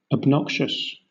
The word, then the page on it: obnoxious: British English